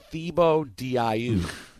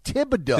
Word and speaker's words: Thibodeau: I know the official actual pronunciation is Thibodeau. But I thought the nickname was Tibbs. – And it what is I Tibbs. His name is pronounced Thibodeau.